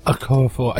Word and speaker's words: Okorafor: Okorafor. Are you keep correcting me on this? I keep getting her name wrong.